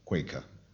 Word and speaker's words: Queen's Acre: Howard Sturgis was a wealthy man who had this sort of gay coterie at his house, Queen's Acre, pronounced Quaker, on the edge of Windsor Great Park.